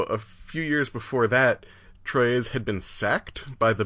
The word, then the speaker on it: Troyes: Bishop of Bishop Ansiges of Troy. Troyes. I really ought to be able to pronounce French better. Troyes. Troyes. Let's go with that.